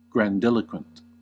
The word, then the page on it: grandiloquent: American English